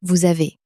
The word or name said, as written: vous avez